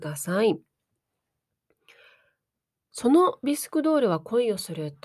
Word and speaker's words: 着せ替え人形: 着せ替え人形と書いて、文字は着せ替え人形と書いて、読むときはビスクドールと読むようですね。